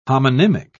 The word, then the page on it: homonymic: British English